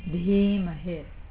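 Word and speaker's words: Dhimahi: So in Dhimahe, the first I is long and the second I is short. Dhi, and you pronounce the H, so it's not D, but Dhi. Dhimahe.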